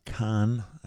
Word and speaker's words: Kahan: Noah Kahan there, or Kahn, I think is the preferred pronunciation. It's spelled K-A-H-A-N, but I think it's just pronounced like Kahn, like William Shatner did in that Star Trek movie.